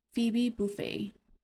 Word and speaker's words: Phoebe Buffay: Rachel introduces her, and her name, Miss Phoebe Buffay. Did I say that right? – Yes.